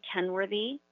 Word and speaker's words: Kenworthy: My name is Beverly Kenworthy, K-E-N-W-O-R-T-H-Y.